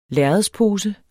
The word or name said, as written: lærredspose